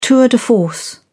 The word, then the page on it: tour de force: British English